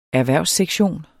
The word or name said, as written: erhvervssektion